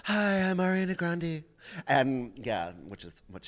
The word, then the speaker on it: Grande: and just to circle back to Ariana Grande, okay. Do you know that her last name is actually pronounced Grandy? – That's so funny.